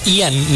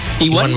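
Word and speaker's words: Ewan: No, o sea, pregunto, porque según yo era Ian, ¿no? Ian McGregor. – No, Iwan. – Iwan McGregor. – ¿Es Iwan? – Iwan McGregor. – ¿Así se pronuncia? – Ajá. – Ok. – O sea, E-W-I-N. – Ajá. Iwan McGregor. No es Ian.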